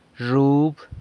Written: zhub

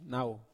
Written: nau

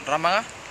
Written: ramanga

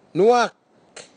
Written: nuhak

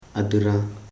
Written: a.di.rã

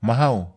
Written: mahau

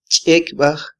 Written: chekybaj

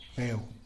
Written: beu

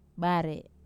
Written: bar

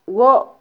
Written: wo

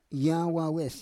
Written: yawawes

